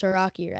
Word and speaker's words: Taraki: So Willie says it's Taraki, and I got like a DM saying, "I'm like, good job commentating. You're the first guy who pronounced Taraki right."